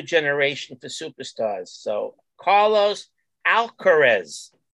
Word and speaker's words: Alcaraz: In tennis, if you didn't follow over the weekend, there's this kid, Carlos Alcaraz. Did I pronounce that correctly, Danny? Alcaraz? – Yeah.